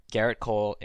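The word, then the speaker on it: Gerrit Cole: One thing I want to talk about is two of the premier pitchers of this year, Garrett Cole and Patrick Corbin. I assume I pronounced those names correctly. – You're 2 for 2 so far.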